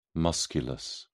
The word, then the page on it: musculus: British English